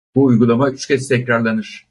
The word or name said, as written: kez